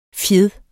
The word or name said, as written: fjeds